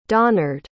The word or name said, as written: donnard